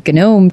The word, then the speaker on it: GNOME: There's one called Yasser, which apparently can be used both in a GNOME terminal window or GNOME terminal window. I'll get shot if I don't pronounce that G in there.